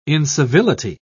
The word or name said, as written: incivility